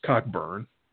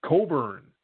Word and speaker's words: Cockburn: That would be pronounced Cockburn. – Yes. – It's not pronounced Cockburn. Apparently it's pronounced Coburn.